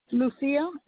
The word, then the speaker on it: Lucia: Lucia, L-U-C-I-A, Zapata, Z-A-P-A-T-A. – You said that first name was L-U-C-I-A? – Yes.